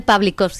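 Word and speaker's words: Pawlikowski: Pavel Pavlikovsky. – Pavlikovsky, que es la rehostia, lo cual me alegro muchísimo.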